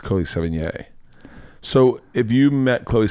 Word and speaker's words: Sevigny: Uh, you can say Sevigny or that, I like Sevigny. – Yeah, that's the French.